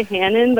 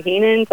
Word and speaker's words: Hanan: Like, so I, so I decided to, I decided to put, and then my last name's hard to pronounce. People want to say Hanan, but it's actually Hanan.